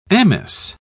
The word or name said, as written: amice